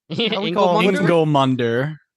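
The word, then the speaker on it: Ingilmundr: And then, oh God, forgive me for names, but Ingelmund? – Ingelmunder. – Ingelmunder. – Is it Munder? Like, the R is pronounced.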